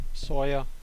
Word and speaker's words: Sawyer: Ähm, ist 94 erschienen von äh, Microprose, von Chris Sa Saber, oder wie wird er ausgesprochen? Sawyer. Sa Sawyer, genau.